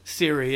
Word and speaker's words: Siri: Is that how you say it? – Siri, yeah.